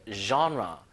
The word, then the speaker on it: genre: 이게 이제 그 영어원의 단어가 아니어가지고 장르란 발음이 이제 genre. genre.